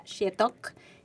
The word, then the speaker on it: Xietoc: Se pronuncia Shetok, se escribe X-I-E-T-O-C.